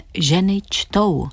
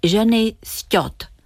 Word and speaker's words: Ženy čtou: Chytře si pomohla anglickým spojením which toe, což znamená který palec. Přestože tento obrat v rodné řeči vyslovila Elsie bezpečně správně, v češtině vždy přečetla ženy sťot.